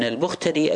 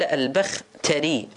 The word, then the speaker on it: البختري: الثالثة، يقول يظهر من مطاوي كلمات أئمة الرجال وعلماء الحديث أن ليث ابن البختري المرادي، لاحظوا قال البختري بفتح الباء والتاء، البخ شنو يقول؟ الباء والتاء وسكون الخاء المعجمة وكسر الراء، يعني راح شلون تلفظ؟ البخ تري، البخ تري، يعني الباء مفتوحة التاء مفتوحة الخاء مساكنة الراء مكسورة، البختري، أنا أقول بختري لا البختري اسمه.